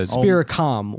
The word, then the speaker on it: Spiricom: Is that how you say it? Spiricom? Spiricon? – Spiricon or Spiricom? – That's like a... – Spiricom with an M.